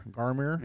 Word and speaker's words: Garmr: And that's how I would guess it's pronounced. G-A-R-M-R. Garmir.